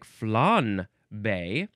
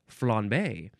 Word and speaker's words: flambé: Now we have the first form of the word flambé. You can also pronounce it, it looks like flan-bé, but just with a subtle N, flambé. But it's spelled with an M. I don't know which is more proper. I should say the spelling, F-L-A-M-B-E, and the E has the accent, the acute accent goes boop.